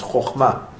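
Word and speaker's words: chochma (חכמה): Like a lot of people say chachma. There's no such word as chachma in Hebrew. It's chochma, chochma, chochma.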